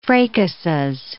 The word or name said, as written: fracases